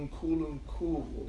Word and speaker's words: Nkulunkulu: You have to get the pronunciation right. Nkulunkulu.